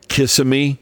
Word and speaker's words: Kissimmee: You may not do much with it, but just in case, because people that say Kissimmee sound really foolish. So it's Kissimmee, Florida.